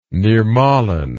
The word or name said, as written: nirmalin